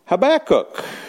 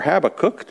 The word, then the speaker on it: Habakkuk: And this is a quote from Habakkuk, or Habakkuk, depending on how you want to pronounce the name.